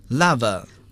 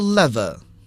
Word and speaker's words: lava: 那火山喷发之后呢，会同时产生很多的物质，其中一个就是熔岩 lava 也可以读作 lava 或者是 lava，表示熔岩，火山喷发之后形成的一种地形结构。